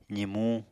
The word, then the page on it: zimuñ: apatani